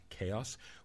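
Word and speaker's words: Xaos: Most recently, the group Chaos, spelled with an X, X-A-O-S, Chaos, which explores the electronic side of Greek music.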